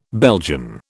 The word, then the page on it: Belgian: British English